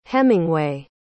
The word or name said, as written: hemingway